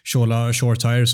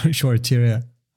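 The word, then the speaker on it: Shoretire: Vi har ju även en Shola Shortire som jag vet inte uttalas så, men jag glömmer fan alltid av hur han ska uttalas. Uh, eh, Chortiria. Ja, visst.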